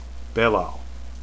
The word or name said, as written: belal